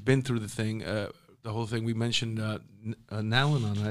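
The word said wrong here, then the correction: Nar-Anon: I keep saying it wrong. – Naranon.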